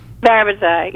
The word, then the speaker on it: parabatai: So they said parabatai, so that's how I say it.